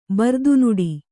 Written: ಬರ್ದುನುಡಿ

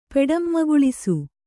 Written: ಪೆಡಂಮಗುಳಿಸು

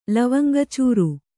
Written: ಲವಂಗಚೂರು

